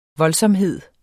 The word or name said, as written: voldsomhed